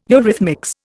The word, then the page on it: eurythmics: Indian